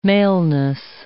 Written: maleness

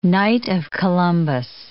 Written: Knight of Columbus

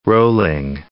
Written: Rowling

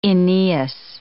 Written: Aeneas

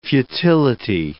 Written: futility

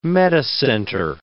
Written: metacenter

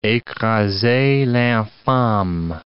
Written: ecrasez l'infame

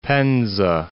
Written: Penza

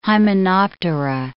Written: hymenoptera

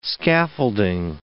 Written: scaffolding

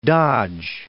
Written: Fort Dodge

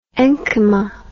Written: enchyma

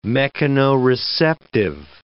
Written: mechanoreceptive

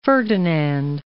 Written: Francis Ferdinand

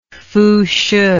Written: fushih

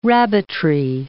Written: rabbitry

